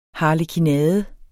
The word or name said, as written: HARLEKINADEN